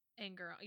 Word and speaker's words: Angarano: The last thing I have to make a note on is just that the theater teacher, played by Michael Angarano. – Angarano.